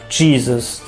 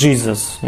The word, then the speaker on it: Jesus: W danym języku czyli na przykład Jesus, nie, dż, nie wiadomo dlaczego. Dźwięk z dż, bo tak się mówiło po angielsku, chociaż wszędzie jest ji, tak naprawdę.